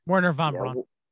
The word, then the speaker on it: Wernher von Braun: Wernher von Braun, if I said that right. – Wernher von Braun. – Yeah, there you go.